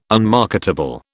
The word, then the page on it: unmarketable: British English